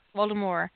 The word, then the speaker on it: Voldemort: Because apparently we've been mispronouncing Voldemort since the very fucking beginning, so... The T silent. Did you know? – I don't fucking think so. – Yeah, is Voldemort? – The T is silent.